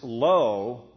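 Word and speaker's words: lo (לא): He uses the particle which is pronounced lo, which is permanent prohibition.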